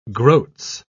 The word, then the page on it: groats: British English